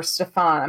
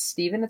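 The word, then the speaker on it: Stephon: The point in time where every referee came out, Stephen Smith, or Stefan, I'm not really sure how that's pronounced, and if it's Stephen, it's wrong. Because it's S-T-E-P-H-O-N.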